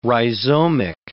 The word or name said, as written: rhizomic